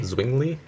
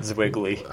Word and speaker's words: Zwingli: Isn't that Zwingli? – Yeah. – Not Zwiggly. – I know, I said it wrong, but we studied him in Christian history.